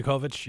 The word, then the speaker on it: Dijakovic: Dominic Dijakovic, am I saying that right? – For the Dijakovic. – NXT. Dijakovic, yeah. – Close enough.